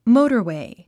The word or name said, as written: motorway